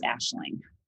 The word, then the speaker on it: Aisling: So it's looks like it's Aisling, A-I-S-L-I-N-G, but it's pronounced Ashling.